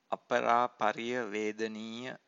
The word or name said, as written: aparapariyavedaniya